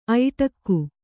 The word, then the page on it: ಐಡೂಕ: Kannada